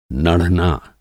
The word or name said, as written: नढ़ना